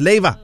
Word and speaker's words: Leiva: After Cape Town and Johannesburg, the official Liverpool FC store has arrived in KZN with club legend Lucas Leiva.